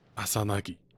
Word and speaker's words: 朝凪: 阿萨纳吉，我特地的学了他日文的发音，他到底是谁呢？